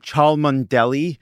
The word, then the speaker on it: Cholmondeley: I actually already knew that Cholmondeley is pronounced Cholmondeley. And because that is so far, I didn't need to put it. Like, if it was closer, I would need to actually put in the phonetics. But because that word is cuckoo bananas and I know it's Cholmondeley, I just said Cholmondeley.